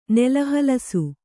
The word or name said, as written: ನೆಲಹಲಸು